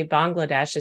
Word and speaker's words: Bangladesh: So I said, is it okay if I say Bangladesh? Because that's the way I've always said it. And I was kind of making fun of my Texas accent. And you pointed out that it's actually Bangladesh is how it's properly pronounced.